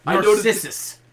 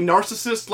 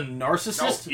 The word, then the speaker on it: narcissist: Narcissus. Right? – Yeah. – My only note for this promo, Heenan keeps pronouncing narcissist like Narcissus, and I cannot let this stand.